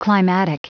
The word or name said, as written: climatic